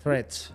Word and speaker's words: Threads: No sé cómo se dice bien. – Threads. – Lo he dicho bien a la segunda.